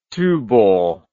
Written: Tuborg

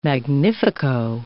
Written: magnifico

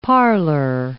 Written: parlour

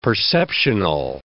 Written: perceptional

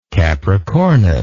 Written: Capricornus

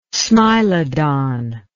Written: smilodon

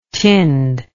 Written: tinned

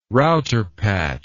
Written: router patch

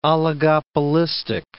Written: oligopolistic

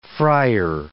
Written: friar

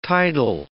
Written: tidal

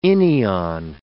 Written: inion

